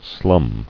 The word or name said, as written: Slumming